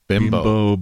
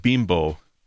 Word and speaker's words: Bimbo: I don't think they understand how that translates up here. – Yeah, right. Exactly. – It's a very derogatory word. It's spelled B-I-M-B-O. It's bimbo in America.